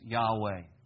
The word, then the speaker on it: Yahweh: In Hebrew, one way to pronounce it is, we don't really know the pronunciation, but it's Yahweh is a common pronunciation of this.